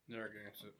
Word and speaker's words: Narragansett: How do I say this? – Natagazeret. – Natagazeret. – Narragansett.